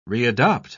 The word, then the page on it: readopt: British English